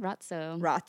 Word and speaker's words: razzo: Now we have Razzo. – Mm-hmm. Rotzo. Yeah, you say the T like pizza. – Rotzo. Oh my God, I've been saying it wrong.